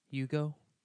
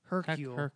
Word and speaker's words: Hercule: Hugo? – I don't think it's Hugo. – What is it? – Hercule.